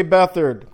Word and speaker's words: Beathard: Beathard, quarterback from the University of Iowa. Oh, I know. I said it right. C.J. Beathard, quarterback, University of Iowa.